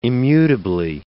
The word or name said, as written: immutably